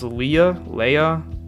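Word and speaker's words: Leah: So this girl, I think her name is pronounced Leia, it's L-E-A-H, Holland.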